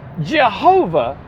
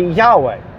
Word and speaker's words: Yahweh: So Jehovah is not how to pronounce God's name. Like I said, scholars of antiquity think it should be Yahweh. But they're absolutely sure it's not Jehovah.